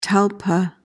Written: talpa

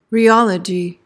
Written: rheology

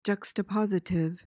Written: juxtapositive

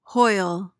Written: hoyle

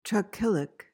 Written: trochilic

